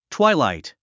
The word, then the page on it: twilight: twáilàit; トワイライト